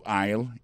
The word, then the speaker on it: aisle: Go to aisle 11 and you will find it. Aisle là, c'est A-I-S-L-E. Vous prononcez aisle.